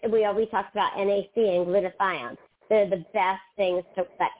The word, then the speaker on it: glutathione: How do you spell it? – G-L-U-T-A-T-H-I-O-N? I-O-N-E. – Glutathione. – Yeah. – Oh, glutathione. – So it's better to get your glutathione from a supplementation or from a patch, really.